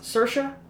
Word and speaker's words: Saoirse: It's pronounced Saoirse like inertia.